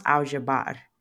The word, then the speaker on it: al-jabr: The original title is Arabic and it's pronounced Al-Jabr. Now, I know I probably butchered that pronunciation, but hey, there's your fun fact for the day.